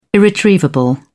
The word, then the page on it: irretrievable: British English